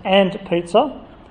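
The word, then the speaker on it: &pizza: The company, called Ampersand Pizza, it's pronounced And Pizza, announced the addition of the Marionberry Knots to its dessert menu.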